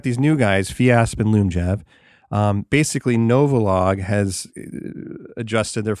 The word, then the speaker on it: Lyumjev: It's L Lyumjev. That's how you pronounce it.